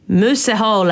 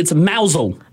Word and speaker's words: Mousehole: It's probably something like moose hole. – That's just offensive. – Or is it like mooshole? – Oh, it's actually mousel. – Mousel. – But it's spelt mousehole. – It is spelt like mousehole.